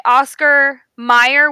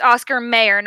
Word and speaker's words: Oscar Mayer: Oscar Meyer with an e versus Oscar Mayer. It's Oscar Mayer, not Oscar Meyer.